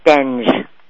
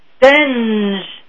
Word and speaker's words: Stenge: Stenge. Did I say it right? – Nope, it's Stenge. – Stenge. Lynda Stenge. – That is correct.